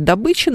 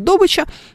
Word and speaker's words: добыча: И вот эти диалекты, люди, которые занимаются добычей нефти, они носители диалекта, согласно которому ударение в этом слове ставится иначе. Так бывает. Поскольку они там большинство, к ним приходят новые люди из других регионов, они вроде говорят «добыча», но все кругом говорят «добыча», и ты тоже начинаешь менять свое произношение в этом смысле.